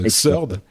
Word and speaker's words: Xrd: Xrd. x euh, en fait, x ouais, Sign, ouais, comme Third, tu sais, comme si c'était le troisième.